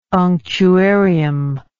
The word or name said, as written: unctuarium